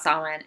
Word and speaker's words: Samhain: So this person is writing in saying, I would love to learn a little more about Samhain, anything you can tell us. So yes, it is pronounced Samhain.